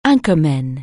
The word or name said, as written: anchorman